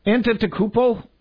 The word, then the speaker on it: Antetokounmpo: Antetokounmpo? – Oh, you just did it!